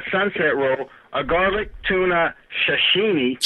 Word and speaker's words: sashimi: Garlic tuna sashimi. – Okay, hold on. – I know he's going to say this wrong. – I can say tashuni. – Sashimi. Tashuni. Yes. That's fish without the rice.